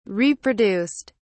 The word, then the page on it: reproduced: British English